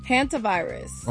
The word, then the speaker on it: hantavirus: Hantavirus.